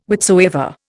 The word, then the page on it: whichsoever: Indian